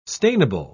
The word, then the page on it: stainable: British English